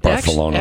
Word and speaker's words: Barcelona: But we ended up in some office at the airport in Barcelona for probably 5-6 hours. – I believe it's pronounced Barth. – Barcelona. Actually, you're correct. It is that Castilian lisp, right? – Okay, big word, again.